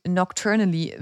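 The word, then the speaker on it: Knockturn Alley: Aber natürlich bei der Nocturnengasse, auf Englisch Nocturne Alley, das heißt übersetzt Klopfdrehgasse, also irgendwie nicht so sinnvoll. Mhm. Aber der gesamte Name wie Nocturnally wird es dann ausgesprochen, was nächtlich bedeutet, mhm.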